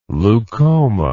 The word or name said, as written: leukoma